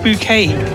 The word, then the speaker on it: Bucket: And she pronounced her name Bouquet.